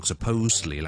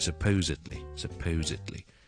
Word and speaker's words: supposedly: 咁啊，希望大家就唔好再讀 supposedly 啦，係 supposedly。多咗一个 syllable 嘅，今日讲到呢度，拜拜。